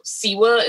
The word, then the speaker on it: Siwa: Cause I posted this JoJo Siwa.